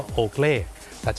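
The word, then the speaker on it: Oakley: คนไทยออกเสียงว่าโอเกลแต่เจ้าของภาษาไม่ได้ออกเสียงว่าโอเกลครับเขาออกเสียงว่าโอเกลีโอเกลี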